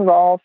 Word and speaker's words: Rohlf: So if you Google Dressage Naturally, or Karen Rolf, and the last name is spelled R-O-H-L-F. Throw that H in there just to mess everybody up.